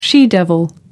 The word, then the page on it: she devil: American English